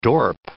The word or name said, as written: dorp